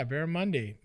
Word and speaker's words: barramundi: Yeah, barramundi.